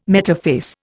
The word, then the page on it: metaphase: Indian